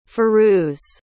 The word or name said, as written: firuz